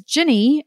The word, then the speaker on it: Gini: when your name is Ginny and it's spelled G-I-N-I, there are never souvenirs with your name on them.